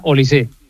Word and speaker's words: Olise: No, es francés, es no lleva acento, pero se pronuncia Olise.